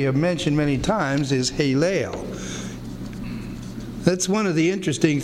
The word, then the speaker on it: Helel: Looks like this in the Hebrew, H-E-L-E-L. Here's the H. Here's the little E, those three dots. There's the L, and here's another E, and then another L. Haleel. Pronounced like A's, Haleel.